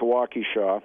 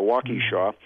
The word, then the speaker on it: Waukesha: This lady, our reader, Dawn, has this photo, and she decides, she hears that Les Paul is coming to Waukesha, I said that correctly, I used to live there, Waukesha, to play a benefit for the Les Paul Museum.